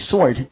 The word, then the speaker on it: sword: 好，所以知道 as every sword has two blades, 记住这里 sword。好，那么 W 是不发音的，所以记住 sword 这个词它表示剑的意思，可以了吗？